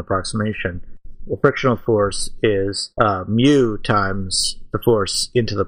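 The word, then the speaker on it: Mu (μ): How do you say mu? – Mu. Yep. Yeah, use the coefficient of friction.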